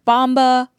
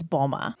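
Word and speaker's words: Mboma: I've heard Bomba, Mamba, Mbamba, but just so we're clear, her last name is Boma.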